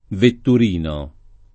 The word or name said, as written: vetturino